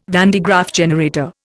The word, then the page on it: van de Graaff generator: Indian